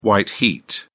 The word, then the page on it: white heat: British English